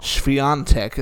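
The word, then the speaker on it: Świątek: Swiatek is how you say it.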